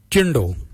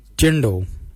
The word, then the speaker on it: djendal: Let's look at jindal. Jindal means white. Jindal. Jindal is spelled D-J-E-N-D-A-L, Jindal. Then we'll look at Jardak. Jardak is grey, Jardak. Jardak is spelled D-J-A-R-D-A-G, Jardak, grey.